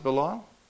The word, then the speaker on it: Belial: do you pronounce it Belial? It's actually Belial, so we're all wrong there.